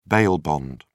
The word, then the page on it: bail bond: British English